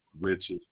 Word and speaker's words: Richard: Richard, you need me to spell it? – Just to make sure I have it correct so it'll pull up your policy. – No, I'm Richard Lewis. – R-I-C-H-A-R-D. – Yes, L-E-W-I-S.